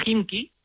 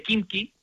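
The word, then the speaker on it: Himki (Химки): Sí, la, la, KH suena como una J nuestra. Por ejemplo, en el caso de Himki, en lugar de Kimki.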